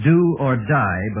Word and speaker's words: die: And the first is do or die, but the die is pronounced D, as if it were D-E-E. Do or D.